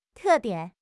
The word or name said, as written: te4 dian3